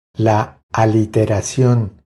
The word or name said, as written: La aliteracion